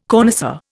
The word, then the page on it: connoisseur: Indian